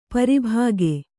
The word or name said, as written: ಪ್ರಭಾಗ